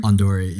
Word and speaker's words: Ondori: Ondori.